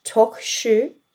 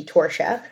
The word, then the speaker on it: Torcheux: Her name is Myra, Torcheux. Did I say that correctly? Or yes. – I think the American accent could probably be Torcheux.